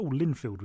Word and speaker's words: Linfield: Also, Linfield was the other one, which is quite handy because it also rhymes with Binfield, as the Belfast Telegraph picked up, and I do point that out in the interview.